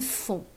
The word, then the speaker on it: font: Faites, and font, and that should remind you of on or son.